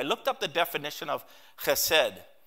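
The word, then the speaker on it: Chesed (חסד): It's spelled H-E-S-E-D. Chesed. It's pronounced, you'd think it's pronounced Chesed, but it's got a guttural sound. And it's pronounced this way, Chesed.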